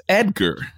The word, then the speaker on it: Edgar: My name ain't Edgar. It's Edgar. There's a D in my name.